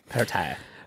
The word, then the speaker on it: Perrottet: It's not Perrottet. What is it? – Perrottet. – It's a silent T. – Perrottet. – It's French. – Perrottet.